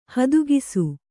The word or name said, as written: ಹದುಗಿಸು